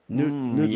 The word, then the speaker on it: Nodlinski: There's a 99-year-old grandpa, Milford Pete, how do you say it, Nudinsky? – Nudlinski? Yeah, he's there in Nebraska. – Nudlinski? – N-O-D-L-I-N-S-K-I. So he's got to be a Polish guy, Nudlinski.